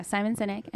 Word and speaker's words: Simon Sinek: Did I say that right? – Yeah, Simon Sinek.